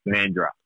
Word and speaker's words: Mandurah: We're going to how do you pronounce it? Is it Mandurah or Mandurah? – Mandurah. – You're going Mandurah. – Being a sand groper now, it is just Mandurah.